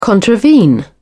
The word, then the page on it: contravene: British English